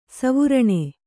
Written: ಸುವರ್ಣ

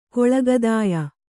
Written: ಕಾಳಗದಾಯ

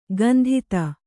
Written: ಗಂಧಿತ

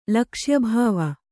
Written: ಲಕ್ಷ್ಯಭಾವ

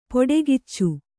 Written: ಪೊಡೆಗಿಚ್ಚು

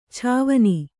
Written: ಛಾವನಿ

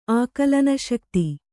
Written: ಆಕಲನಶಕ್ತಿ

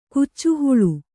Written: ಕಾಚಿಹುಲ್ಲು

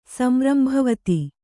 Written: ಸಂರಂಭವತಿ

